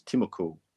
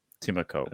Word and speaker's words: Timoko: Albert Hopewadi and Matthew Tomoko. Did I pronounce that right? – I think it's Timoko. – Timoko.